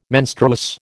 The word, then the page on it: menstruous: American English